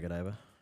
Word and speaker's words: Godiva: Yeah, Godiva.